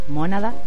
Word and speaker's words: Mónada: Em, bueno, el disco está circulando por YouTube, Mónada, con acento en la O.